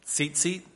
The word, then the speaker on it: tzitzit: The Hebrew word is spelled S-I-S-I-T, and it's, bear with me here, it's pronounced tzitzit, tzitzit, tzitzit, let's just go with that, tzitzit.